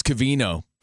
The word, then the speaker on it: Covino: My name's Covino, pronounced with an Ovino.